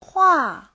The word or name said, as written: hua4